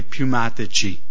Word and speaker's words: Pneumatici: P-N-E-A-M-A. Da qui noi prendiamo i pneumatici, no? Non riesco a pronunciarlo, abbiate pietà di me. Pneumatici. Perché in inglese non pronunciamo la P.